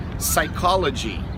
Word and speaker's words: psychology: A terceira palavrinha de hoje é psychology. Repete comigo: psychology. Então, no português, a gente fala psicologia. No P, né, no português, no P, a gente vai pronunciar a letra P.